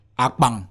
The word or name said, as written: akpang